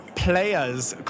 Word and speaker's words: Playa's: Tell me about Encore Playa's Club. – I love that you properly pronounced it. It's technically spelled players, but you'll soon understand that it's intended to be thought of as Big Pimpin'.